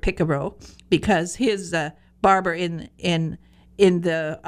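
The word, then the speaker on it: pícaro: But in fact, it comes from a Spanish word that rhymes with Figaro. It's picaro.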